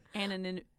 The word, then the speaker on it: anonymity: It's like sea anemone. – You gotta say it right. It took me a while to say it. – It's anonymity. – Anonymity. – Oh my lord. – My brain is just shorting out. It does. Because we like emphasize the T.